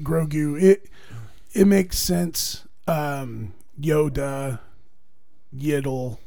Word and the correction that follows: Yaddle: Yaddle, whatever.